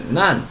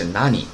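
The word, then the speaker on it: 何: 那么这个“ 和” 的读法啊，这个“ 和” 的话，我们目前为止呢学过两个读音，一个是 nan，一个是 nani，哈。然后我们看一下什么时候呢读成 nan，什么时候读成 nani。那么目前为止的话，我们什么时候读成 nan，读读成 nan 呢？嗯，首先第一点必须要记住，那么后续量词的时候必须读成 nan，nan sai，nan kai，对吧？是这种啊。必须读成 nan，后续量词必须读成 nan 啊，然后看一下他给的这个后续助词偷的时候要读成 nani 啊，nani to nani o kaimaska，哎，你要买什么和什么呀？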